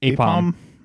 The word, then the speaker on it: Aipom: Apeom. – Apeom. Okay. – A-I-P-O-M.